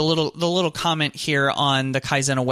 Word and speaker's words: Kaizena: And the first one I wanted to touch on is this thing called Kaizena. It's spelled K-A-I-Z-E-N-A.